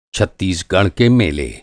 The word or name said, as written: छत्तीसगढ़